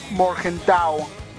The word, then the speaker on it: Morgenthau: Y la fotografía es de Kramer Monge. A ver, ayúdame, que no lo puedo pronunciar bien. – De Kramer Morgenthau.